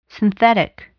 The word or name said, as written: synthetic